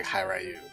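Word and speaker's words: Hiryu: The way they break it down in the manual, it's like, and I've always think of Ryu as Ryu, so it's got to be like Hi Ryu, right? – Yeah, it's definitely 2 syllables, so it's got to be. Yeah. Hi Ryu.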